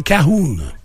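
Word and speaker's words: cajón: Si t'as dit Cajun, c'est parce que... – Ça s'écrit de même. – Non, c'est ça, mais ta prononciation de l'espagnol, tu l'as pas.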